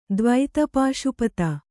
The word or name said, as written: ದ್ವೈತಪಾಶುಪತ